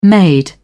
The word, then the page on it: made: British English